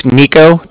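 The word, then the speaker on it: Niko: So my name's Nico. That's N-I-K-O. – Okay. – I'm calling from Cardiff, and that's spelled C-A-R-D-I-F-F, as in Frank Frank.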